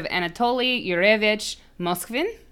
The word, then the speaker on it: Anatoly Yurevich Moskvin: This is the case of Anatoly Yurevich Moskvin.